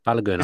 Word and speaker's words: Balogun: How do you say his name? – Balogun, I think. – Balogun, okay.